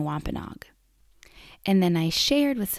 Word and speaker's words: Wampanoag: So I read something created by indigenous peoples, but who weren't Wampanoag people, that said to pronounce the name Wampanoa. So I made all of the episodes saying Wampanoa. And then afterward, when I was done recording them, I listened to a Chappaquiddick Wampanoag person named Carrie Roselle Smith say the same word, but she pronounced it Wampanoag.